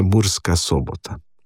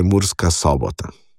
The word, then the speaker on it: Murska Sobota: Bi pa tu dal primer, ki se meni zdi kot prekmurcu dokaj problematičen, mnogim mogoče ne, to pa je Murska Sobota. – Ja. – Mnogi rečejo tukaj Murska Sobota. Ne, dejansko je ta sobota z oskim ojem izgovorjena, tudi je logično, ker če mi jemljemo to sombat oziroma sobota, odkoder to izhaja, ne, je tukaj tuje ime, Ne?